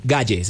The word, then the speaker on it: Gaye: Marvin Gaye, se escribe. – Airbnb. – Gaye, como suena.